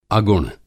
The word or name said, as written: अगुण